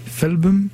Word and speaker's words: Felbm: F-E-L-B-M. Felbum.